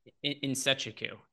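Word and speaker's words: Nzechukwu: In Sechiku is how his last name is pronounced. Devin Clark is a much easier name to say than Nsechukwu.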